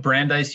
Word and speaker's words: Brandeis: Yep, Brandeis University. Brandeis, got you.